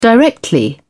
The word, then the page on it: directly: British English